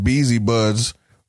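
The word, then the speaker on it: Beazy Budz: At Beezy Buds on pretty much everything.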